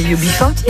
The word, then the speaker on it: UB40: UB40.